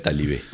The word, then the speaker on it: Talibé: Así que vamos a escuchar a este africano, este senegalés, Nurukane, cantando Talibé, o Talibé se escribe, pero él lo pronuncia Talibé.